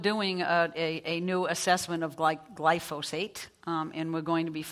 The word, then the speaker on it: glyphosate: The World Health Organization recently said that glyphosate, did I say that right? – Glyphosate, yeah.